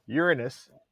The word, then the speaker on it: Uranus: But the deepest one I read into is Uranus. That's right. Uranus, that's proper pronunciation.